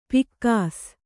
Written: ಪೀಕಿಸು

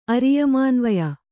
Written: ಅರಿಯಮಾನ್ವಯ

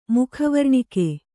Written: ಮುಖವರ್ಣಿಕೆ

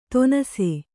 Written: ತೊನಸೆ

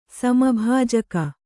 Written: ಸಮಭಾಜಕ

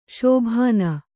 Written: ಶೋಭನ

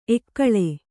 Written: ಎಕ್ಕಲ

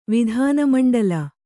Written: ವಿಧಾನಮಂಡಲ

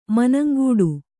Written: ಮನಂಗಿಡು

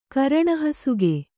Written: ಕರಣಹಸುಗೆ

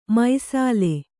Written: ಮೈಸಾಲೆ

